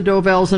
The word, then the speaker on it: Dovells: Del Vos. – Number six. – I'm not saying it right. – That's all right. You know it's the Dovells, and I'll give it to you for that.